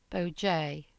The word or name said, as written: Bojea